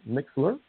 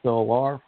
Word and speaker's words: MixLR: Some people pronounce it Mixler, but it's MixLR, for easy for me to remember.